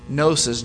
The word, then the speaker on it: Gnosis: For Timothy and the church in Ephesus in the first century, it was primarily a heresy known as Gnosticism, spelled with a G, Gnosticism. We've talked about it before, Gnosis, G-N-O-S-I-S.